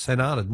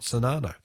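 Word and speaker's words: St. Arnaud: Bill from Ballarat says, by the way, St. Arnold is pronounced St. Arnold, not Sonano.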